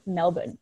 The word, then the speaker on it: Melbourne: Also, as like a sidebar, I just feel like as an American, you sound like such an asshole pronouncing Melbourne, you know? – Melbourne, yeah. – Like, I know. Just let me say Melbourne. Like, just let me pronounce the R because I feel super pretentious otherwise.